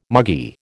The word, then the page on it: muggy: American English